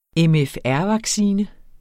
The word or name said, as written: mfr-vaccine